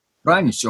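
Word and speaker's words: Pranić: Yes, you it's Pranich. Pranic. – Pranic, okay. – Yeah. – And how do your co-workers, let's say, call here in America? – Oh, they call me Pranic, and then they have like, how do we pronounce this? I was like, well, basically it's panic with letter R right after P.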